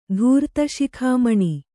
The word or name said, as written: ಧೂರ್ತಶಿಖಾಮಣಿ